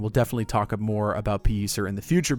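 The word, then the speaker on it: Pellicer: So this is the more correct pronunciation of Pelliser. It's pronounced Pelliser.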